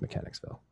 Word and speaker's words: Mechanicsville: Um, so shout out and uh, Monk, Monkville, Monkville uh, hydro facility. – Oh, I pronounce it Mechanicville. – Mechanicville, Mechanicsville. Yeah, I was just way off there. It's Mechanicsville.